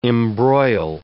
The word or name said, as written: embroil